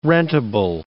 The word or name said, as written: rentable